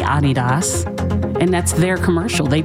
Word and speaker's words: Adidas: It's not pronounced Adidas. Mm-hmm. We're the only people in the world that pronounce it Adidas. – Okay. – Adidas is how to say it. Adidas. Because the guy who founded it is a German guy named Adi Dassler.